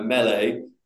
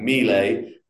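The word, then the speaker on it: mêlée: Once when I thought I was telling the scrum half to put the ball into the scrum, which is a melee, but I pronounce it with my West Country twang, melee, which I'm told is a washing machine brand.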